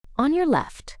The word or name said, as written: on your left